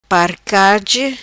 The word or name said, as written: Parcage